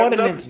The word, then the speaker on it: ordinance: I'm pronouncing it wrong. But where if you have crime happening around your business, they can shut you down. – Oh, yeah. – Your business yeah, ordinance. Nuisance.